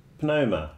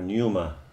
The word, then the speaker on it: pneuma: So they would say part of the problem is that the psyche got separated from the pneuma or pneuma. But it's you spell it with a P, the pneuma.